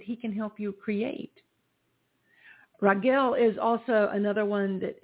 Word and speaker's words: Raguel: The Archangel Raguel, I always want to spell it, pronounce it the way it looks. It's R-A-G-U-E-L, but it's pronounced Raguel.